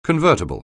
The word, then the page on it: convertible: anglais